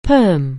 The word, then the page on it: perm: anglais